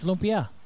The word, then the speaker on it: lumpia: No, the way you just said it. – Yeah, it's Lumpia. – Is that the way it's pronounced? I'm not sure it is. – Yes. Yes, yeah. It is.